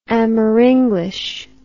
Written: amerenglish

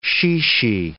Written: chichi

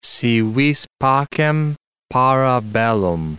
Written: si vis pacem, para bellum

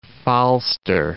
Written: Falster

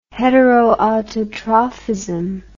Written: heteroautotrophism

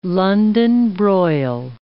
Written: London broil

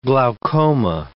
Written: glaucoma

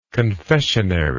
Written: confessionary

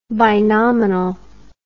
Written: binominal

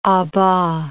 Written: a bas

